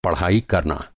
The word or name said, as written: पढ़ाई